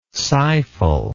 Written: sighful

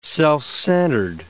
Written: self-centered

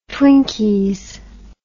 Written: twinkies

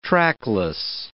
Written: trackless

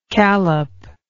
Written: callop

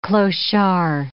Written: clochard

